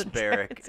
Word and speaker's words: Beric: Thankfully, this Barrick is spelled B-E-R-I-C.